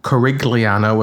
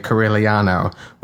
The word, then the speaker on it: Corigliano: I pronounced it Corigliano with a hard G, and this is a person I know who is a singer and has studied Italian, and I think she knows how to say Corigliano with a soft G or even an invisible hidden G, silent E, as they used to say on the electric company.